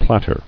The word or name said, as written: Platter